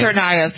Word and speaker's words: Tsarnaev: Russian authorities reportedly told the FBI that this particular, I never can pronounce the name, Zarnaev, is that how you pronounce his name? – Tsarnaev.